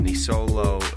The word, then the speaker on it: Nisolo: He's the co-founder and CEO of Nisolo, a not-your-everyday, mostly shoe company that has baked into its mission a commitment to sustainability and ethically made products.